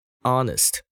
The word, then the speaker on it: honest: Honest 诚实的，那它的名词是 honesty，这个字要注意它的嗯最前面的 h 是不发音的，所以念 honest。